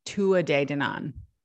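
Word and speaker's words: Tuatha Dé Danann: And the way that they pronounced Tuatha De Danann was Tua De Danann. So I don't think that you pronounced the TH in that.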